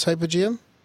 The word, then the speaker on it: hypogeum: Is it in Egypt, this hypogeum? – No, the hypogeum is in Malta. – How do you spell it? Hypo. – Hypogeum. – Hypogeum. Oh, okay.